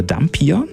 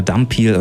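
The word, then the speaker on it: Dhampir: Also es ist, er ist ein Halbvampir, ein sogenannter Dampir. – Ein Dampir? – Genau. Oder Dampir, oder das wird unterschiedlich ausgesprochen oder benannt.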